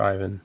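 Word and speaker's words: Iwan: He's on Twitter as I, oh, it's I W A N. I would say that's Ivan.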